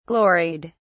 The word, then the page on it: gloried: British English